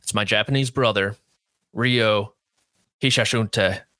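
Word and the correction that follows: Ryo Hisatsune: Now, I cannot make those same noises, but it's something like Yo Hisatsune. – Yo Hisatsune. – Hisatsune. – Hisatsune. – They have a very silent R, and then it's like Hisatsune.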